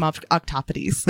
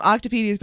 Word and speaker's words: octopodes: I love calling them octopodes. For a long time, I was calling them octopedes, but I was doing the wrong pronunciation. It's octopodes, and I love it.